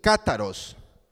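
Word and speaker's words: cátaros (καθαρός): El término que se aplica aquí en el original o el adjetivo limpio proviene de un griego que se pronuncia así, cátaros. Este cátaros que habla de limpieza se usaba en la literatura griega para referirse a la limpieza física, cultural y ética.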